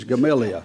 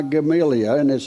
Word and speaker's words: Gamaliel: I looked that up on how to pronounce it on the internet, and I heard two or three different types, so I thought I'm just going to redneck it. He's Gamaliel. That's just the way it comes out of my mouth.